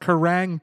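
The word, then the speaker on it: Kerrang!: Kerrang properly.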